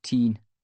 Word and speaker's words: Tyne: Everyone calls the river the Tyne. Back then, we pronounced it Teen.